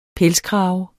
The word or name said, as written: pelskrave